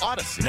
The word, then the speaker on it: Audacy: We are Odyssey. Yep. Spelled like it sounds. A-U-D-A-C-Y. – Yep. – Odyssey.